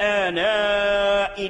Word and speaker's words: آناء: نحو من آناء الليل، آناء الليل بعد الهمزة، همزة آناء تتوجد لياء مرسومة في المصحف، هل تلفظ بهذه الياء؟ لا يجوز، ثابتة في الرسم محذوفة في اللفظ.